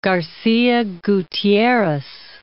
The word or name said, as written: Garcia Gutierrez